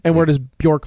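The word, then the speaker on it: Björk: Bjork.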